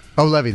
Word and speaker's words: Levy: Eugene and Dan Levy. Levy, that's right. Sorry. I always get it wrong. – It's okay. The wrong emphasis on the wrong syllable.